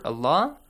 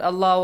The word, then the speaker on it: Allah: But whenever we have illa, whenever we have dhamma or fatha before the word Allah or Allahumma, we pronounce it as? – Heavily. – Allah. Heavily. Allah.